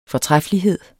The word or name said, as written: fortræffelighed